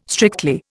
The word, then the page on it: strictly: Indian